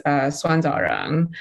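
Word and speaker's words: suanzaoren: The Chinese pronunciation is suanzarang.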